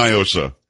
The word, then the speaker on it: NIOSA: Do not, because it's definitely Niosa. It is not a Spanish word.